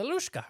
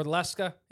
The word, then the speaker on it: Lusca: Okay, the Lushka or Leska. It's pronounced a couple different ways. Right. I call it the Lushka.